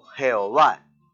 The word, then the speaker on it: Jehová: un nombre transliterado que se pronuncia Yahvé o Jehová.